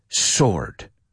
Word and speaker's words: sword: La W no se pronuncia. Hay gente que pronuncia, pero suena un poco raro, amigos. No se dice sword, tanto que sword. O sea, apúntalo y tacha la W. Sword, right?